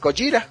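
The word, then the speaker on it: Gojira (ゴジラ): Medina nos comenta que a la hora de romanizar la J, la pronunciación se cambió, o sea que los norteamericanos se fueron directo con Godzilla, y pues significa precisamente lo mismo que en japonés, Gojira.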